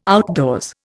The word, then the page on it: outdoors: Indian